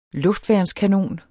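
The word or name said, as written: luftværnskanon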